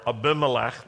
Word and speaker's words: Abimelech: Avimelech's servants and the wells of Abraham. There was one point where Abraham met with Avimelech or Abimelech. That's how you say it, Southern style.